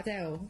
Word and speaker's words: Adele: Because if you're going to say it how she says it, so if it's written phonetically to read, it's Adele.